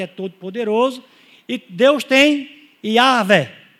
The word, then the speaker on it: Yahweh: Yahweh, ou Yahvé, ou Jeová. Você vê que Jeová é uma, uma invenção, tá? Mas Yahweh ou Yahvé é, é a pronúncia mais apropriada.